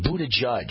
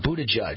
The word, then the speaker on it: Buttigieg: Buttigieg. Yeah, Buttigieg.